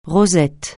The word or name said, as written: rosette